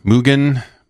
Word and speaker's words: Mugen: So is it Hard G Mugen? Yeah. That's I assume also it is pronounced in the Japanese as well.